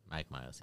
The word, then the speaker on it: Mike Myers: Mike Myers.